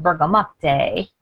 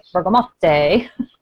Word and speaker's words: bergamot: And I just learned recently, some people say bergamot and some people say bergamot. And our bergamot is sourced in Italy and they call it bergamotte so we pronounce it with the T now. I used to pronounce it the other way with the silent T, but now I'm pronouncing it more like the indigenous peoples of Italy, bergamotte.